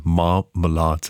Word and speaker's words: Marmalade: Marmalade, das ist eine Kooperation, ein Projekt hier aus Deutschland, klingt ein bisschen wie Marmelade und wird auch ganz ähnlich ausgesprochen. Marmalade sind zwei Worte, M-A-R und dann Malade.